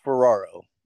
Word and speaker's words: Ferraro: It's Ferraro. – And Ferraro. – Yeah, and if you're really cool, you can roll the R's.